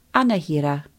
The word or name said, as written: anahera